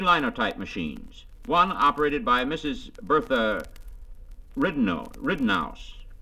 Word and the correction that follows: Ridenhour: Pardon me, Dan, how do you pronounce that? – That's Bertha Ridenhour. – Ridenhour, I beg your pardon.